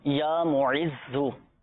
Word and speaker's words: معز: مذلو اور پہلا جو صفات میں زال کے ساتھ ہی ہے، زال ہے، یا معزو یہ زا کے ساتھ ہے نا، تلفظ کی درستی کا بھی آپ نے خیال رکھنا ہے.